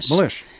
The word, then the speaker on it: Milish: Milish. Am I saying it right? – Yeah, no, it sounds like Milish.